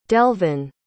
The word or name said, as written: delvon